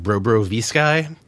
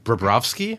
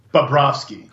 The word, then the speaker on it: Bobrovsky: There was a guy named like Brobro V-Sky. I don't know who he is. I think it's pronounced Bobrovsky. – Bobrovsky.